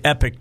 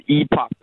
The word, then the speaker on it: Epoch: Is it the epoch or is it the epic times? How do you pronounce it? – It's the epoch times.